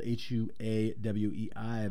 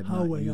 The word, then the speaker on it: Huawei: H U A W E I. – Huawei, I believe it's Huawei. Pronounced.